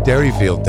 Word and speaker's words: Derryfield: I've heard people say, yeah, I'm going up to the Deerfield deck. Like, no, you go to the Derryfield deck. – I'm going to Deerfield Park. – Deerfield Park. It's Derryfield Park, you dummy.